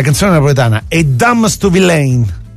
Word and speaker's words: Villain: Non Villain, come molti sono convinti, perché la vera pronuncia del suo cognome è Villain, quindi l'accento sulla I.